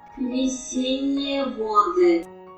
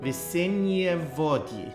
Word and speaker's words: Весенние воды: My Russian is nothing to write home about, but this apparently is the pronunciation of Spring Waters in Russian. – Весенние воды. – Thank you, Google Translate. Весенние воды.